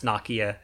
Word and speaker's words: Nokia: I think it's actually pronounced Nokia, and the Americans mispronounce it.